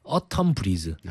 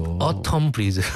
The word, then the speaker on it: 어텀 브리즈: 애기봉 플레이리스트 어텀 브리즈. 아. 가을에 바람이 슬슬 이제 오는 거죠. – 어, 발음 좋았어. – 어텀 브리즈. – 어, 좋았어, 좋았어.